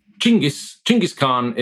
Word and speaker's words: Chinggis Khan: I think if you want to, you get Chinggis Khan is how most scholars say it properly, but I'm a pragmatist in this regard, rather than strictly politically sensitive and correct.